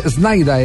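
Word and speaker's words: Sneijder: Oiga, eh, eh, es que hay una historia con, con, eh, con Snyder, eh, Snyder es como se pronuncia en, en, holandés. Wesley Snyder. Snyder, Snyder. Se escribe Snyder, pero ese es Snyder.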